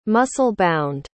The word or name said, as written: muscle-bound